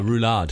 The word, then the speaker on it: roulade: Roulade. – Roulade.